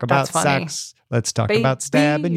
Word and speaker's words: seax: that's how you pronounce that word that is spelled S E A X. – Yeah, sax. Sax, like yeah. sax, like a saxophone.